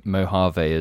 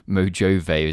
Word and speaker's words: Mojave: Plus, Alex pronouncing Mojave as Mojove is the funniest fucking shit in my life, and it fucks me up to this day.